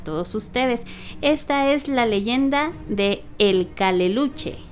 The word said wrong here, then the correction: Caleuche: El Caleuche.